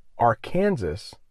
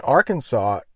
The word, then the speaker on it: Arkansas: Arkansas looks like it should be pronounced Arkansas, but it's not. Don't ask me why. It's Arkansas, and Arkansas is the headquarters for Walmart.